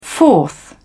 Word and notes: fourth: British English

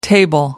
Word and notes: table: American English

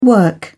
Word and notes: work: British English

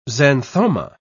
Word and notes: xanthoma: British English

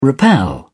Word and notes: rappel: British English

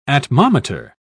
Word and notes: atmometer: British English

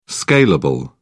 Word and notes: scalable: British English

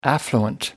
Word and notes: affluent: American English

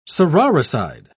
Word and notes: sororicide: British English

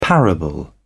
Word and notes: parable: British English

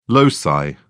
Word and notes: loci: British English

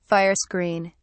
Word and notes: fire screen: British English

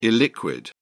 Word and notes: illiquid: British English

